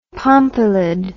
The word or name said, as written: pompilid